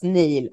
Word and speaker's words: Niall: Han har väldigt märklig stavning, men det ska uttalas Neil.